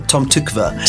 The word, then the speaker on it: Tom Tykwer: Tom Tykwer.